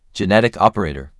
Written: Genetic operator

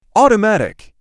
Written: automatic

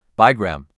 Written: Bigram

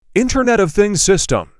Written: internet of things system

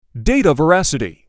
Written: Data veracity

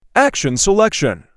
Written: action selection

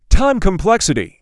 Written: Time complexity

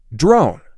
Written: Drone

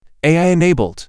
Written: AI-enabled

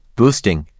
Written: Boosting

